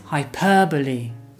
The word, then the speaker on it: hyperbole: The correct pronunciation is hyperbole. Hyperbole.